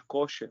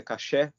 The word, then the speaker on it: kasher: ou alguns chamam de kosher, mas a pronúncia correta é caché,